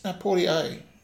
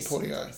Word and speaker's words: Poitier: Poitier. – Is it? Is that how you pronounce it? My apologies. – Sidney Poitier. – Sorry. – Yeah. Well, that's how he pronounced it, so...